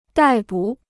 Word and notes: 逮捕: dài bǔ

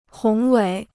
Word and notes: 宏伟: hóng wěi

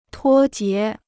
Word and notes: 脱节: tuō jié

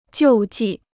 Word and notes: 救济: jiù jì

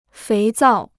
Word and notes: 肥皂: féi zào

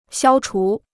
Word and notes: 消除: xiāo chú

